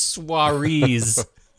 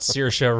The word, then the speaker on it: Saoirse: It's Soirees, not Saoirse Ronan. Who's that? It's Soirees.